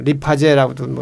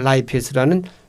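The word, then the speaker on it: lipase: 하여튼 뭐 리파제라고도 뭐 그냥 발음이 좀 우리나라식으로 한국들이 발음이지만 영어는 라이페스라는 그 굳기름 효소가 이자에서도 나오고 또 어디서 나와요?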